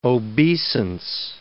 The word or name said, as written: obeisance